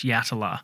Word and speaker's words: Yatala: I think also we discovered ourselves as we went along that we discovered that we'd pronounced, or I'd pronounced Yatala as Yatala maybe in an earlier episode, but we realised it was Yatala, the prison, which comes up quite a lot throughout the South Australian season.